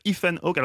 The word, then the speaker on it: Ethan Hawke: On va parler de ce très beau film avec Catherine Deneuve, Ethan Hawke.